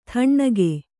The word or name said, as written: ಥಣ್ಣಗೆ